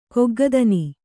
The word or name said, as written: ಕೊಗ್ಗದನಿ